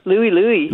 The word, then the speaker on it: Louis Louis: Is it the Louis Louis? Is that how you pronounce it? – Louis Louis.